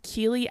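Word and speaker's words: Keeley: So my Instagram, my business Instagram is Keely Abigail Photography. It's K-E-E-L-E-Y.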